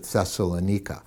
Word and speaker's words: Thessalonica: It's the first letter that we have that he writes to a church he planted in the city in northern Greece, the city of Thessalonica, or many people pronounce it Thessalonica.